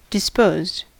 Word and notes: disposed: American English

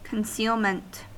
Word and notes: concealment: American English

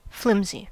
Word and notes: flimsy: American English

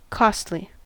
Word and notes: costly: American English

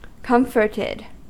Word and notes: comforted: American English

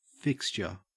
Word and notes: fixture: Australian English

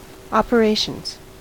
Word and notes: operations: American English

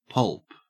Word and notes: pulp: Australian English

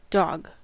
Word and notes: dog: American English

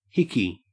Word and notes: hickey: Australian English

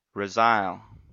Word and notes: resile: American English

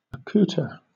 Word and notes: accouter: British English

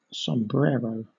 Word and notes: sombrero: British English

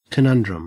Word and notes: conundrum: Australian English

